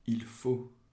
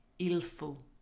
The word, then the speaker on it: il faut: Il faut. – Faut is spelt F-A-U-T and il, like we've learnt before, I-L, meaning he or in this case, it. Il faut.